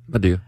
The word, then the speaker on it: Madea: Al says I always say it wrong. – Madea.